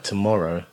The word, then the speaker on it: tomorrow: Say the word like tomorrow has got the, it needs to have the stress on the second syllable. You can't go tomorrow.